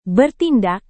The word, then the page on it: bertindak: indonesian